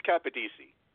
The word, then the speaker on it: Capodici: I know I mispronounced that, didn't I? – We had lessons right before the show. Now let's try that again. – I know, and I totally just mispronounced that. Please say your last name. – It's Capadisi.